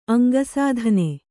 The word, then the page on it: ಅಂಗಸಾಧನೆ: Kannada